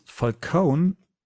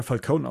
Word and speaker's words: Falcone: Dass Falcone hier Falcone ausgesprochen wird und nicht Falcone, wie es noch in Batman Begins ähm, der Fall war.